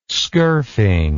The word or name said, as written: skurfing